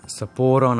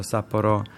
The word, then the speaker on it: Sapporo: Sapporo namesto Sapporo.